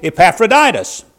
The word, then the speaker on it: Epaphroditus: Yet I supposed in labor, I supposed it necessary to send to you Epaphroditus. That's the right way to pronounce that.